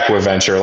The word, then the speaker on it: AquiVenture: Yeah, AquiVenture, like acquisition and venture, AquiVenture.